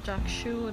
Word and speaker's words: Chakshu: If you guys are wondering what this is, my name is Chakshu. It's spelled as C-H-A-K-S-H-U, and welcome back to my podcast, guys.